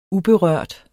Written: uberørt

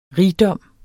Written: rigdom

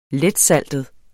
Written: letsaltet

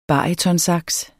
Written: barytonsax

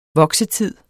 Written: voksetid

